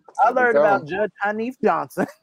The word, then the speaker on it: Henif: It's spelled H-E-N-I-F, pronounced Hanif.